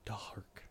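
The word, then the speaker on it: Darhk: It's spelled all weird. It's like D-A-H-R-K or something. Dark. – Dark. – It's pronounced dark.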